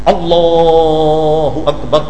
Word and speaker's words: اللہ اکبر: سیدھا تلفظ کیا ہے؟ اللہ اکبر, یہ صحیح تلفظ یہ ہے.